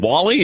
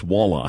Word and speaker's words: walleye: I said, that's walleye. – Walleye. Walleye. Not Wally backward.